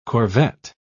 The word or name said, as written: corvette